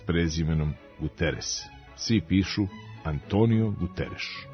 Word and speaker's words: Guterres: Tu S uvek ostaje S, pa Guterres u Portugaliji može da bude Guterres, ali kod nas on je Guterres.